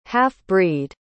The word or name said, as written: half-breed